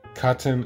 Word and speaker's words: Chatten: Auf Platz 9 widmen wir uns den Katten, C-H-A-T-T-E-N geschrieben, im Zweifelsfall spreche ich das dann fälschlicherweise auch immer sehr gerne Chatten aus, aber Katten ist die eigentliche Aussprache.